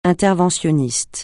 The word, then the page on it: interventionniste: francais